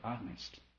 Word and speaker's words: honest: Our experiences, you will get the answer if you're honest. H-O-N-A, you know, H is silent.